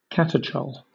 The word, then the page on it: catechol: British English